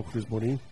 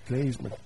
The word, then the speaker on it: klezmer: Ela também é promotora daquele festival Cris Morim. – Klezmer.